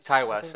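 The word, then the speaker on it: Ti West: West, written and directed by T. West. I think it's really Ty West, sorry. – Ty West? – Is it Ty West or T. West? – I think it's Ty West. – Whatever, it's spelled T-I. – I think it's just supposed to be Ty.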